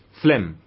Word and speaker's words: phlegm: And the fourth humor is phlegm. The spelling of phlegm is P-H-L-E-G-M. The G is silent, okay?